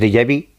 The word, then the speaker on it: déjà vu: lo que llamamos déjà vu, que eso, mira que viene pronunciado.